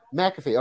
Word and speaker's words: McAfee: McAfee, okay. I was saying McAfee. Yeah. I was emphasizing the wrong thing.